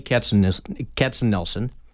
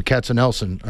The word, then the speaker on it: Katzenelson: We're joined with Vitaly Katzenelson. Vitaly, I hope I'm pronouncing that somewhat close. – Hello? – The pronunciation of your name, Katzenelson, are we getting it right? – Yeah, you got it, yeah.